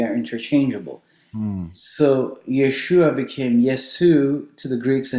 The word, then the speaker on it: Yeshua: It's the same Hebrew word, Yeshua. When we say Jesus, we're saying Yeshua, we're just pronouncing it in the Greco-Roman pronunciation.